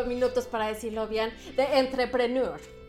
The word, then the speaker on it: Entrepreneur: el honor de ser parte de Entrepreneur. Yo, técnicamente se debería pronunciar entrepreneur, pero entrepreneur también sirve.